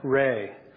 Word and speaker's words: Rey: And in that movie, there's a character named Rai. I think I'm pronouncing her name right. Rai, is that right? For all the little ones? No? Rey. Is it Rey? Oh, thank you.